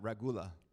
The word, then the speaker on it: regula: So I have in my notes ragu, like spaghetti sauce. Just say it like that, ragula.